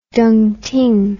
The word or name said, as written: dongting